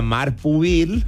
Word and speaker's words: Marc Pubill: Y despierta cada día a Alex Baena y a Marc Pubill.